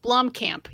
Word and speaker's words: Blomkamp: Blomkamp. You put an extra L in there.